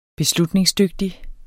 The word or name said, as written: beslutningsdygtig